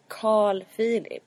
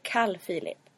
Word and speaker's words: Carl Philip: Ja, det har rört på sig i Sofia och Carl Philips relation. – Ja. Eller Carl Philip. – Carl Philip. Noterade ni det, kära poddlyssnare, att kungen inte säger Carl Philip. Han säger Carl Philip. Carl Philip. C-A-L. Mellanrum.